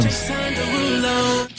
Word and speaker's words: Derulo: Jason Derulo.